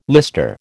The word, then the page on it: lister: American English